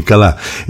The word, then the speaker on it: καλά: Δεν πάμε καλιά. Συγγνώμη, καλά.